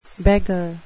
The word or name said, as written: beggar